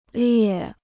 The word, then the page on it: 裂: liè